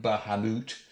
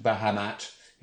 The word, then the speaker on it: Bahamut: The original pronunciation is the moot version, Bahamut, although the mat, Bahamat, is more common these days.